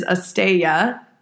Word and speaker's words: asteya: The third one is asteya.